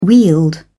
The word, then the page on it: wield: British English